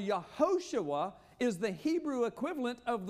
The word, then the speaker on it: Yehoshua: You say Joshua in Hebrew, Yehoshua, and that is Yahweh or Jehovah in the Germanic pronunciation of it, is salvation.